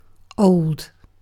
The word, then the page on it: old: British English